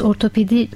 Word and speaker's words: ortopedi: Çünkü toplumda bunu birçok kere insanlar farklı telaffuz edebiliyor ama aslında kullanılması gereken kavram ortopedi kavramıdır.